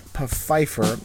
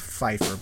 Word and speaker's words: Pfeiffer: from a blogger by the name of Eric Pfeiffer. I guess it's Pfeiffer, but I always go Pfeiffer because the silent P.